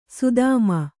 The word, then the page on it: ಸದುಮ: saduma